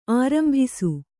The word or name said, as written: ಆರಂಭಿಸು